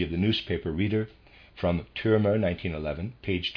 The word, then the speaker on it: Türmer: Readers, aside, Türmer is spelled T-U-R-M-E-R, Türmer, end of readers aside.